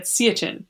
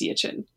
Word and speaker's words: Siachen: I always pronounce it as Siachen, and then I heard an Indian person pronounce it and they said Siachen. So I'm probably doing it wrong, but I've always in my head read it as Siachen, but I think it's Siachen.